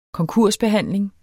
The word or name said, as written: konkursbehandling